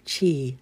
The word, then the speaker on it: Qi: The term Tai Chi is often translated as supreme ultimate, pronounced C-H-E-E, Qi.